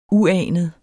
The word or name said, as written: uanet